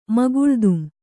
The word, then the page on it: ಮಗುಳ್ದುಂ: Kannada